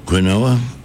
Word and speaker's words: quinoa: It's served with an Israeli couscous, a quinoa salad. I hope I'm pronouncing that correctly. Quinoa? – Flamoa? I don't know. – Sounds good to me. – Q-U-I-N-O-A.